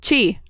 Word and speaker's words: Qi: Chi.